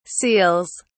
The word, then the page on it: seals: British English